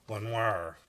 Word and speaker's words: Lenoir: I live in Lenoir. I think it's Lenoir. We would pronounce it Lenoir, North Carolina.